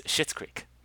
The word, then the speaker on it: Schitt's Creek: It's Schitt's Creek. – Okay, all right, just earning that explicit tag there. – excuse me, it's a last name, and it's spelled S-C-H-I-T-T, okay? – Yeah, okay, yes, I'm aware.